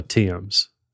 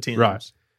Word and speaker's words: Thames: I think they call it the Thames. I think that's how they pronounce it. – The Thames.